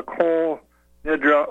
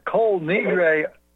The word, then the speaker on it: Kol Nidre: This is a what they call the Kol Nidra oath. And if I can add to that. – Go ahead. – That Kol Nidre, or is another pronunciation for oath, was exposed by Arthur Koestler in his book, The 13th Tribe.